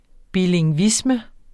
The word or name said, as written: bilingvisme